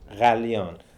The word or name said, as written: Kalyan